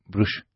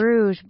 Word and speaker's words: Bruges: And we left for Bruges. How do you say Brujas? It's very difficult for me. – Bruges. – Bruges. – Bruges.